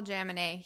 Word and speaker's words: Jaminet: Paul Jaminet. He is the Perfect Health Diet. – Jaminet. I knew I was saying it wrong.